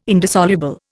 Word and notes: indissoluble: Indian

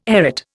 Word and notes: arête: Indian

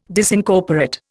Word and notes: Disincorporate: Indian

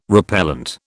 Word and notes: repellent: British English

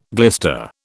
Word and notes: glister: British English

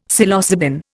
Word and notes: Psilocybin: Indian

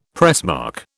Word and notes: pressmark: British English

